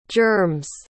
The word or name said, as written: germs